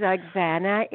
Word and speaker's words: Oxana: My name is Oxana. It's spelled O-X-A-N-A.